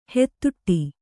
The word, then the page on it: ಹಾದಾಡು: hādāḍu